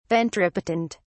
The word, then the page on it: ventripotent: British English